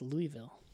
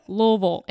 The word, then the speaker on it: Louisville: It's Louisville. It hangs out deep in your throat. Yeah. So. with long, lots of syllables.